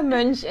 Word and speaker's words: Mönch: Eiger, Mönch, and Jungfrau.